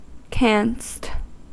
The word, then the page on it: canst: American English